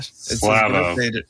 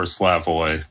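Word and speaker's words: Slavoj: By the way, happy birthday, Slava. – Slavo. – Slavo? – That's right. – Yeah, Slavo or Slavoy. Pronunciations differ. But yes, it is Slava Zizek's birthday today.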